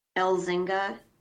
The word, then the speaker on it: Elzinga: Nanette, N-A-N-E-T-T-E, Elzinga, E-L-Z as in zebra, I-N-G-A, okay.